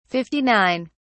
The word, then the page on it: 59: British English